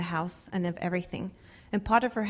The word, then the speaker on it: Potiphar: Is that how you say it? Potiphar? Potiphar? – Potiphar.